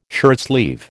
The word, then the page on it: shirtsleeve: American English